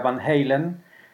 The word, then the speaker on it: Van Halen: En realidad se pronuncia Van Halen.